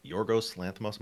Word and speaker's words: Yorgos Lanthimos: Um, Yorgos Lanthimos, am I saying that correctly? Mm-hmm. I, I yep.